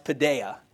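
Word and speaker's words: paideia (παιδεία): It's actually a Greek word that's pronounced paideia.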